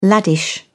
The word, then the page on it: laddish: British English